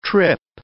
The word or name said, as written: trip